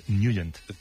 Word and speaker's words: Nugent: Hombre, siempre lo hemos llamado en el barrio Tetnagen o Tetnagen. – Claro, yo era de Tetnagen. – Pero la pronunciación correcta es Nugent.